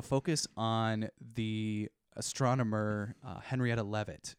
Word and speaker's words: Leavitt: It's pronounced Leavitt.